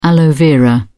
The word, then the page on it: aloe vera: British English